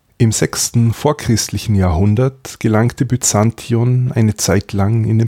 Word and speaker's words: Byzantion: wurde auf der europäischen Seite durch Megara die Kolonie Byzantion gegründet, oder modern ausgesprochen Visandion der Legende nach wurde der Ort nach dem mythischen Heerführer Byzas benannt.